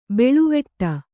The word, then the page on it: ಬಳುವಟ್ಟೆ: baḷuvaṭṭe